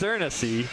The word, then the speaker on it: Eernisse: How would you pronounce the name if you were a listener? E-E-R-N-I-S-S-E. And it is Ernesy.